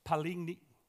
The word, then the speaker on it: palingenesia: It's palingenesia. Palingenesia is how you say it.